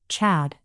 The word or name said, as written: Chad